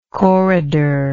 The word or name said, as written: corridored